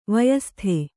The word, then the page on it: ವಯಃಸ್ಥ: vayahstha